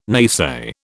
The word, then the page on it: naysay: British English